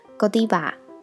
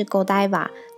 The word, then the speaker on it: Godiva: 那再来就是很常听到的巧克力品牌 Godiva。其实，呃，Godiva 或者是 Godiva 都有人念，就只是差别在于偏法语的发音或是偏英语系的发音而已。